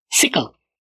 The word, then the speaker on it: sickle: Sí, bueno, es que los ingleses lo pronuncian bien raro, no dicen cycle, y no es tampoco tan pronunciado el cycle, sickle. Es como lo pronuncian los ingleses.